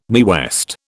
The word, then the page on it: Mae West: British English